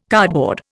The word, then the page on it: cardboard: Indian